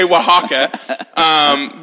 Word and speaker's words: Oaxaca: I really pronounced that one. – That's how you say it. – Yeah, she gave me a thumbs up. Yeah, La Carta de Oaxaca, because they had, it's a reasonably priced restaurant, and you can get a lot of food for and it's tasty.